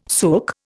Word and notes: suk: Indian

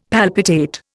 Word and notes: palpitate: Indian

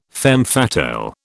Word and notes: femme fatale: British English

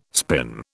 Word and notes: spin: British English

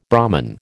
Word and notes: Brahman: American English

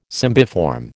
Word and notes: cymbiform: American English

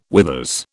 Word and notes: withers: British English

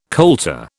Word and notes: coulter: British English

